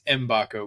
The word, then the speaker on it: Mgbako: We've clarified his last name is pronounced Mgbako. We kind of butchered that the last pod, but we won't do that again moving forward.